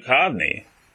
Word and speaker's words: Duchovny: David Duchovny, is that how you say it? – David Duchovny. – Yeah, Duchovny, showing up in drag.